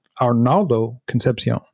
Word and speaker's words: Arnaldo Concepcion: Our first guest is Arnaldo Concepcion.